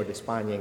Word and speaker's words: Despaigne: pronounced de Spain or de España in Cuba, various pronunciations.